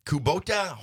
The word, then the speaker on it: Kubota: Yield to your neighbor and Kubota Hometown Proud. Did I pronounce that right? – You did. You nailed it.